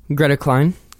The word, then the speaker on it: Greta Klein: Like, there's an interesting quote here in your book from, I believe it's from Greta Kiln, am I pronouncing Greta's name correctly, from Frankie Cosmos? – Greta Klein. – Oh, I'm sorry, Klein.